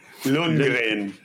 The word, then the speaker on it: Lundgren: Did I say how do you say his name? – Lundgren.